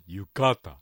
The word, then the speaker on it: 浴衣: はい、浴衣、これは新潟ですと二文字目のかにアクセントが来て浴衣、ね、それから薬、これも薬と言いますし、テレビ、これもテレビなんていうふうに言う人もいます。